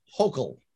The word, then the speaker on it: Hochul: So following up with that, what are your thoughts and opinions of Governor Hochul Kombucha, however you pronounce that? – Her name is pronounced Hochul. – Hochul. – Hochul. – Okay. – Yes. Not Kombucha. – Like Yokel, Hochul. Not Kombucha.